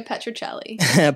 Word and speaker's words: Petruccelli: Yes, Lucia Petruccelli.